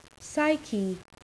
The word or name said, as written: psyche